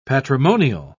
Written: patrimonial

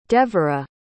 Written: devorah

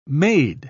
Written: made

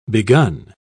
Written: begun

